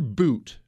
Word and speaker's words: Bout: His name is Victor Boot, spelled like Bout, B-O-U-T, but Victor Boot is his name.